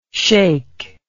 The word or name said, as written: shaykh